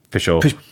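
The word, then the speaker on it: Peychaud: Pichot.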